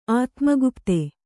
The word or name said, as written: ಆತ್ಮಗುಪ್ತೆ